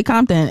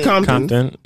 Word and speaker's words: Compton: Somebody was like, well, how do you say Compton? And somebody said Compton. – Compton. – Like, it's not really like a T. It's more like a D.